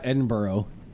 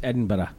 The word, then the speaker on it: Edinburgh: And I said it correctly because I was taught when I got there, it's not Edinburgh, it's Edinburgh.